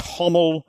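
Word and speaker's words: Gomel: And I was aware that Gommel could also be pronounced Hommel.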